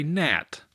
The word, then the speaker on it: gnat: For example, if I said tang, T-A-N-G, you would say gnat, which starts with a silent G. So here you go. Number one is bonk, B-O-N-K.